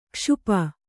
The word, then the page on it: ಕ್ಷಪೆ: kṣape